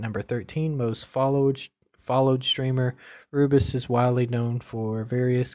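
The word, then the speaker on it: Rubius: M-E-R-C-S Mirks. The number 9 is Rubus. I believe is how you pronounce that. It's R-U-B-I-U-S. Rubus.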